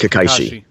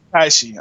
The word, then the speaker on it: Kakashi: Kakashi. – Kakashi, okay.